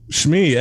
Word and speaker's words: Shmi: Anakin's mom. Shmi. S-H-M-I. Shmi. – I don't think that's how it's pronounced. – What else would that be? Shmi? – I don't know. Shmi. – Yeah. No, it's Shmi. It is Shmi.